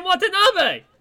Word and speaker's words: Watanabe: Ken Watanabe. Yes, Ken Watanabe!